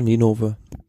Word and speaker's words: Ninove: Ähm, eben hast du schon die alte Ausgabe, wo dreimal Quickstep geführt hat, angefügt omlet het, het Gent nach Ninove. Oder so ähnlich ausgesprochen? – Ja, Ninove.